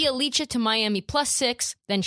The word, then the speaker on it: Bjelica: Do you pronounce it Bjelica? – It's Bjelica. – Bjelica. – Yeah. – Bjelica. Okay, cool. – That's how I've heard it said.